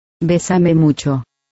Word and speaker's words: Bésame mucho: Bésame mucho. – I think you said it wrong. Try it like this. – Bésame mucho.